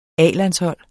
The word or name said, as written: A-landshold